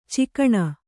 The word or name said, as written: ಚುಕ್ಕಣ